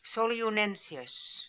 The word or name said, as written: Solioonensius